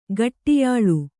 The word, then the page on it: ಗಟ್ಟಿಯಾಳು: Kannada